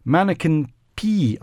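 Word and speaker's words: Manneken Pis: One of its displays is the 700 outfits that adorn what is probably Brussels' most famous single site, Mannequin P, I'm going to pronounce it. – And I won't correct you. – P-I-S, it is spelt.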